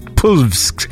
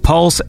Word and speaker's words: PVLSX: Here's a track by Pulse, although it is spelled P-V-L-S-X, like Pulvsk. Anyway, it's Pulvsk. But I believe it is pronounced Pulse.